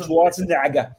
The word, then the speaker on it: Schwarzenegger: Arnold Schwarzenegger y Abigail Breslin. – No, lo, lo, lo, lo pronunciaste muy bien. Arnold Schwarzenegger.